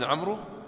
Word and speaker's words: عمرو: طيب، هذا عمرو يعني من باب الفائدة فيها حرف الواو، فكيف ننطقها؟ نقول عبد الله بن عمرو، ها؟ إنزين، ليش مكتوبة واو؟ عشان الفائدة منها بس خسارة حبر يعني، ها؟ ليش؟ يعني شو الفائدة من ذكر حرف الواو في اسم عمرو ونحن ما ننطقها؟